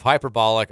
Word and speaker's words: hyperbolic: Trevor said hybolic instead of hyperbolic. I do love you, though, Trevor. Look, you can't expect Trevor to pronounce hyperbolic correctly. – Yeah, I dropped the P.